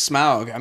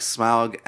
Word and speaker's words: Smaug: I mean, I want to see an Iron Man versus Smaug. I mean, that's I want to see this. – Sure. Man, okay, so honestly, really like Smaug as a...